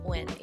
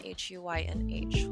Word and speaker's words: Huynh: So it's at Chanel, ChanelC, and Huynh, Huynh, which is a last name that everybody pronounces very differently.